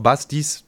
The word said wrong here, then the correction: Basidis: Dach. – Nee, also fast auf Basidis Dach.